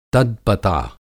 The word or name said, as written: तद्पता